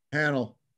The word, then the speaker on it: Hanel: Hanel.